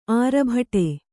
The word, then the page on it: ಆರ್ಭಟ: Kannada